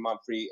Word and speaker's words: Mumphery: So, it's, my Facebook page is my name, Quentin Monfrey. My last name is M-U-M-P-H-E-R-Y, not R-E-Y.